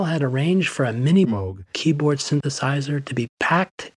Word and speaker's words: Moog: In Germany, it's a fairly common name, and there it's pronounced Moog. – Robert Moog. Moog. – Pronounced Moog.